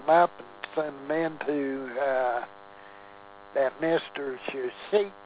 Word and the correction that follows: Shinseki: It's pronounced Shinseki.